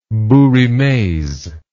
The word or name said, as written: bouts rimes